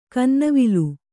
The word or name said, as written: ಕನ್ನವಿಲು